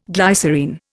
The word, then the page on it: glycerine: Indian